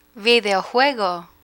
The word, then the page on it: videojuego: Spanish